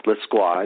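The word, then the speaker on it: Lescroart: You might not know his name pronounced like that, though, because if you've read his books, you know it's L-E-S-C-R-O-A-R-T. But we pronounce it Lesqua, and John is the author of 28 novels, 19 of which have been New York Times bestsellers.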